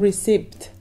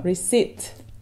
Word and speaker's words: receipt: Not receipt. – No. – Receipt. – Receipt. The P is silent.